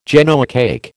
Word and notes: Genoa cake: American English

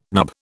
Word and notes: nub: British English

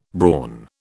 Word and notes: brawn: British English

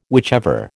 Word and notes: whichever: American English

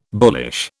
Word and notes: bullish: British English

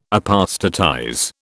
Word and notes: apostatize: British English

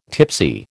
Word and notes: tipsy: American English

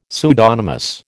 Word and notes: pseudonymous: American English

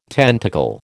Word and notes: tentacle: American English

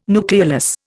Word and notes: nucleolus: Indian

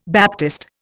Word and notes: baptist: Indian

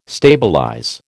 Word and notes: stabilize: American English

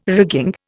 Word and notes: rigging: Indian

